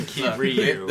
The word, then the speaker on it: Kiryu: R-Y-U. – Kiru. – We call it Kiru here. – If you must, then Kiryu. – This is the South. – Kiryu? – Kiryu.